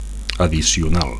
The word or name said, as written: addicional